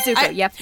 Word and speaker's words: Zuko: Something in my gut is like, that Zuko dude, I got his name right? Zuko? – Zuko, yep, you got it.